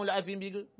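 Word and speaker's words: الأثيم: الیتیم، هی طعام الیتیم میگفت. قال ابن مسعود نمیتونی طعام الاثیم بگی؟ گفت نه من نمیتونم، تلفظش برام سخته.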